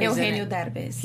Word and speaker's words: Eugenio Derbez: Eugenio Derbez.